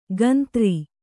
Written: ಕಂತ್ರಿ

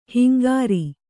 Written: ಹಿಂಗಾರ

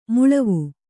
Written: ಮೇಳಾವ

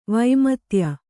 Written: ವೈಮತ್ಯ

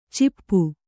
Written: ಚಿಪ್ಪು

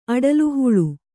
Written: ಅಡಲುಹುಳು

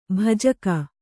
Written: ಭೋಜಕ

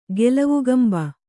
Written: ಗೆಲವುಗಂಬ

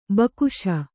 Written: ಭಿಕ್ಷು

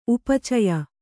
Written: ಉಪಚಯ

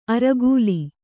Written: ಅರೆಕಾಲ್

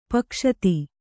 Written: ಪೈಗಸ್ತಿ

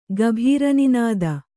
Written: ಗಭೀರನಿನದ

